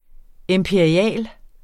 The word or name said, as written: imperial